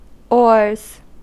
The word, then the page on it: oars: /ɔːz/; /ɔɹz/